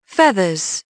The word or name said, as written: feathers